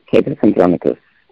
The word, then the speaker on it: Titus Andronicus: Titus Andronicus.